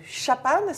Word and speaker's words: chapan: On va commencer par le chapan, c'est la bonne prononciation Tout à fait.